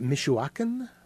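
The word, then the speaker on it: Michoacán: Is it Michoacan? Am I pronouncing that? – That sounds great to me.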